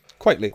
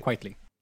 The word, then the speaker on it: Quitely: Like, so is it, I can never pronounce his name, Frank Quitely or Oatley or? – Quitely. – Quitely. It's a kind of a play on words of quite frankly.